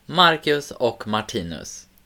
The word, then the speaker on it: Marcus och Martinus: Marcus och Martinus.